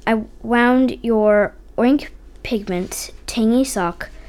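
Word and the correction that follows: Targhee: Targy Sock, sorry.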